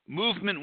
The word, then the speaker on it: MVMT: and that's Movement Watches. It's spelled MVMT.